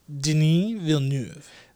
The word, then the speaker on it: Denis Villeneuve: Uh, sorry, can you pronounce that correctly for me? – Denis Villeneuve.